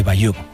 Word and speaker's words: Bayeux: El tapiz de Bayeux.